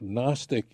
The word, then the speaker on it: Gnostic: You can know, like Gnostic, you know that it's spelt with a funny G in the beginning, even though it's not there, so you can recognize oddities and so on.